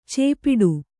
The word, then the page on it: ಚೇಪಿಡು: cēpiḍu